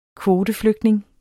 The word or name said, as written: kvoteflygtning